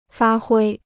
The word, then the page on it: 发挥: fā huī